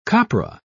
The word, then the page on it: copra: British English